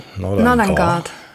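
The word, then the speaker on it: Nordangård: Nordangard. – Mhm. Das D spricht man mit, okay. Das ist Schwede, ne? Weil die Dänen können ja nicht mal ein D. Also Nordangard, okay.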